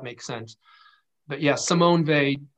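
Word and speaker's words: Weil: Simone Vey, Vey spelled with a W, EIL, Andrew.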